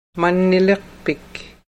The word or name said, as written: manniliqpik